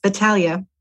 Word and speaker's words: Batalha: And that happens to be Natalie Batalha. Did I pronounce that right, Natalie? – That was really good. Yes, Batalha.